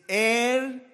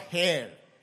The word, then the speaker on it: heir: It is heir and not hair. H-A-I-R is hair, and H-E-I-R is heir.